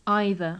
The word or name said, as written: either